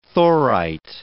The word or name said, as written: thorite